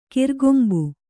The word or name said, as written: ಕೊರೆಕಂಬಿ